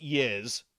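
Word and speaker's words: Jizz: Now, we have to point out that in Dutch, it is pronounced Yiz.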